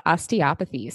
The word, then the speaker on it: osteopathy: And the other question I had was, you talked about your background in, I hope I say this right, osteopathy. Osteopathy. – Yeah, right. You can say it depending on what region you're in, you can say it either way.